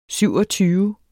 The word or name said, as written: syvogtyve